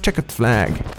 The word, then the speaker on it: Checkered Flag: C'est comme ça que ça se prononce, Checkered Flag.